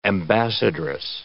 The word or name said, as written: ambassadress